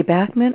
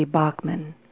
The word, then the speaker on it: Bachman: He records with and tours the world with legendary classic rock icon, Randy, and you're going to have to tell me, Colin, am I pronouncing this correctly? Is it Randy Bachman or Randy Bachman? – Well, Randy likes to introduce himself as, "Hi, I'm Randy Bachman from Bachman Turner Overdrive." So both work.